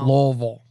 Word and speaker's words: Louisville: I meant Louisville. – Oh. – Louisville. – Yeah, it's pronounced Lou.